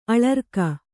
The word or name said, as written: ಅಳರ್ಕ